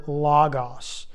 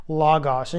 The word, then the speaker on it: logos: Now, the phrase the Word, okay, Word, W-O-R-D, Word comes from the Greek word logos, logos. And you've probably seen this many times, especially in Bible studies and such. It's spelled L-O-G-O-S.